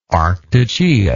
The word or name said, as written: arctogeic